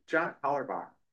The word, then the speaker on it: John Hollerbach: John Hollerbach.